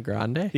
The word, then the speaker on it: Grande: It's like the coffee. – It's Ariana Grande? – Yeah. It's not Grande.